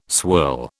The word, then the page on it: swirl: British English